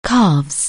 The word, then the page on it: calf: anglais